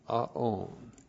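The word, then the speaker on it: Aon (עָוֹן): Aon. Ion, A, long O, N, Aon.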